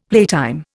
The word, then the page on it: playtime: Indian